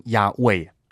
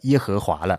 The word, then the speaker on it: 亚卫: 其实呢，按照考据的话应该是读成亚卫，然而从中世纪之后，这种将错就错呢就变成读成了耶和华了。